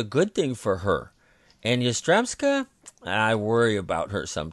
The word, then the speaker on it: Yastremska: Let's see if I can pronounce this, Yastremska. – Yeah, Yastremska.